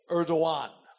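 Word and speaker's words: Erdoğan: It is spelled Erdogan, but they say Erdogan.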